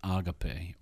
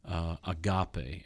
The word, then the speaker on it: agape: In this case, the word is agape, or agape, as some people would pronounce it.